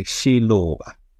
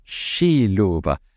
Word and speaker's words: Xiluva: Xiluva. Did I say it correctly? – No, it's a Xiluva. – Xiluva.